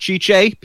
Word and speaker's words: Tite: Their manager, whose name is T-I-T-E, is pronounced Chiche. People are going to get that wrong.